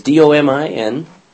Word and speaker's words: Dominicans: Dominicians. Domining. Is Dominicans. How do you pronounce that? Dominicans. It is the Dominicians, but the Dominicans. Dominicans, thank you.